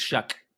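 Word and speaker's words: Shough: It's Shuck. Yeah. – It rhymes with my favorite word.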